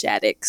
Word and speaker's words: Schadeck: Most people can't pronounce my last name. They always ask. It's Schadeck. So, you know.